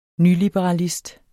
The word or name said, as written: nyliberalist